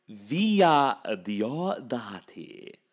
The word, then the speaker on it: Villa Diodati: How do you pronounce it? – Villa Diodati. – Thank you. – That's my fake Italian accent.